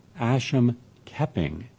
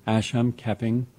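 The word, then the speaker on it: Asam Keping: and it talks about the Asham Keping, let me spell that, A-S-A-M, Asham Keping, K-E-P-I-N-G.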